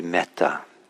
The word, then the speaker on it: metta: And finally, I'll say that the password, it'll be password protected, the Zoom room, and the password will be metta, so the Pali word for loving kindness. It's spelled M-E-T-T-A.